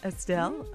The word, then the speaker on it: Estelle: It's by Estelle. Is that how you say her name? – Estelle.